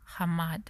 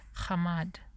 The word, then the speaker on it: chamad: C-H-A-M-A-D. Hamad. Hamad. Maybe that's how it's pronounced. Because the C-H, sometimes they pronounce it with a H. Hamad.